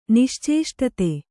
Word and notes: ನಿಶ್ಚೇಷ್ಟಿತ: niścēṣṭita

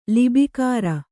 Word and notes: ಲಿಬಿಕಾರ: libikāra

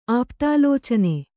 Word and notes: ಆಪ್ತಾಲೋಚನೆ: āptālōcane

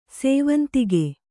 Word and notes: ಸಾವಂತಿಕೆ: sāvantike

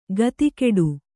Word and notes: ಖಾತಿಗೆಡು: khātigeḍu

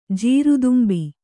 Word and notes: ಜೀರುದುಂಬಿ: kannada

